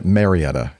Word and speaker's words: Marietta: No, Marietta is correct. Marietta. – Okay. – Yeah. – Okay. – In fact, I used to have a Marietta address.